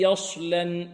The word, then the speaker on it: يصلى: والبعض يضم شفتيه في الصاد، يقول هكذا الذي يصلى، يصلى، يص، خطأ، لا، الذي يص، يص،